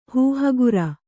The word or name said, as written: ಹೂಹಗುರ